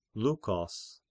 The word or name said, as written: Lykos